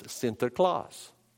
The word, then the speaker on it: Sinterklaas: The Holland expression was Sinterklaas, Sinterklaas, Sinterklaas. Which is the Dutch pronunciation? It's the Dutch pronunciation of Saint Nicholas. So the way the Dutch said Saint Nicholas was Sinterklaas.